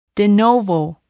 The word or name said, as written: de novo